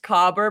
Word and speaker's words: caber: And the second thing is that it is spelled C-A-B-E-R, but the internet told me it's pronounced cobber, and if that's wrong, I'm so sorry.